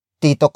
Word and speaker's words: Tito: You can say, Tito ko siya. Tito ko siya. Tito ko siya.